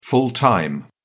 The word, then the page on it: full time: British English